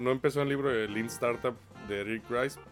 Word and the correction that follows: Ries: Eric Ries, no sé cómo se pronuncia, pero él lo, popularizó. Ries. Ries, Eric Ries y Eric este, Eric Laughter.